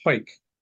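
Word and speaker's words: Hawick: I've got two practices, one in a town called Galla Shields and the another one in a town called Hoyk, which is spelled Haywick, and most people who don't live in Scotland really murder it.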